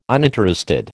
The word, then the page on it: uninterested: American English